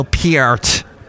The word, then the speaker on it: Peart: Two amazing musicians, of course, and Neil Peart, as I have been told I have to pronounce his last name now.